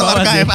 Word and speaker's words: archive: Ini bacanya archive kan, archive. – Archive? – Aduh. – Bener gak? – Bener gak? – Bener gak teman-teman? – Salah. – Salah. – Yang bener apa dong? – Iya bener sih. – Betul, archive. Ada sih tulisannya sih, bener sih.